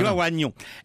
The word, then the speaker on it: oignon: Et un des exemples gag, c'est le i de oignon. Extraordinaire. – Pourquoi oignon? – On dit oignon. Eh ben, parce que les Romains avaient un GN qui prononçait comme on prononce diagnostic, signum. Et avec l'évolution de la langue, c'est devenu ni.